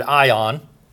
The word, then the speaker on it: aion: It comes from this Greek word ion, A-I-O-N, as we would spell it.